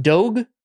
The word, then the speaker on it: Doge: It's pronounced Doge? – Yeah. – Okay. I don't know. I have no idea. – I mean, I don't Does Miyagi style, know? – Like a dojo.